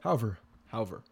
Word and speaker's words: Hauver: The third round they took Trevor Hoover, Hauver. How do you say that? – Hauver.